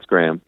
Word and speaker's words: Graeme: I'm probably mispronouncing it, but G-R-A-E-M-E. Graham? – That's Graham.